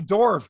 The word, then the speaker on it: Dorf: No, but just the name Dorf just sounds like, hey, you know. – Rhymes with dwarf.